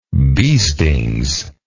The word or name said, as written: beastings